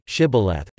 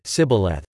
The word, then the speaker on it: shibboleth: Then they said to him, Now say, Shibboleth, and he said, Sibboleth, for he did not accomplish correct pronunciation.